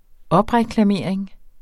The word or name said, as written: opreklamering